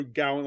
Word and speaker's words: Gawain: And I'll pronounce that better when we get to that episode, because Gawain is not right, and of that I'm actually fairly certain. – Yeah, you're probably, yeah. – It's more Gowan, like closer to Samhain, Gowan.